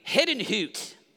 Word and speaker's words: Herrnhut: I said, right there, Herrnhut. And she goes, oh, yeah. You mean Hidden Hoot. I said, sorry, I grew up in Oklahoma. I thought it was Herrnhut. Been saying it wrong.